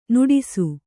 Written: ನಟಿಸು

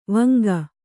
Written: ವೆಂಕ

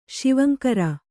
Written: ಶಿವಂಕರ

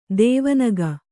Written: ಧ್ವನಿಗೈ

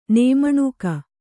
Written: ನೇಮಣುಕಿ

